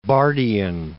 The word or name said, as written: Barthian